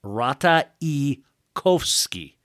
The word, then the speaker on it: Ratajkowski: The Polish pronunciation is Rata Ikowski.